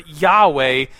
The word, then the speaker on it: Yahweh: And so the word Jehovah is actually, it's kind of not that cool of a story, it's actually a mispronunciation of the word Yahweh coming from the Middle Ages.